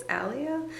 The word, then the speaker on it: Aaliyah: I think I'm saying her name wrong. Aaliyah Shawkat? – I think it's Aaliyah.